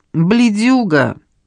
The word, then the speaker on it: бледюга: «Ты ж бледюга», ударение на последний слог, «с интеллигентной семьи».